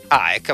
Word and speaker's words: AEK: La pronuncia è senza dire K finale, ecco AEK appunto, capito? Si fa così con la lingua.